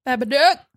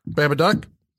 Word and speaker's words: Babadook: Babadook. – Babadook. – Duck rhymes with book.